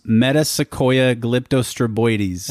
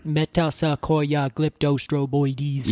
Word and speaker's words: Metasequoia glyptostroboides: Met-a-se-KOY-a glyp-to-stro-BOY-des. – Met-a-se-KOY-a glyp-to-stro-BOY-des.